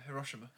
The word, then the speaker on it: Hiroshima: You know the nuclear weapons that was um, released in um, is it Hiroshima? Is that how you pronounce it? – Oh yeah, Hiroshima.